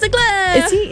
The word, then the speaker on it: Leclerc: Charles Leclerc.